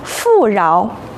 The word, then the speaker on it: 富饶: 这里面的饶要读作二声，请同学们和老师一起读富饶。